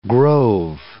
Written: grove

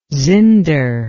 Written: zinder

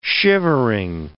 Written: shivering

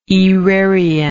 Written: aerarian

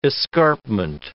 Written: escarpment

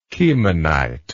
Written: chemonite

